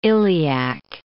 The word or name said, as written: iliac